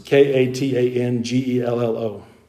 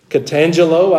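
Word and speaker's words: katangello (καταγγέλλω): The Greek word is katangello. I wanted to say jello when I was reading that earlier. Katangelo, I believe, is the southern version of pronouncing that.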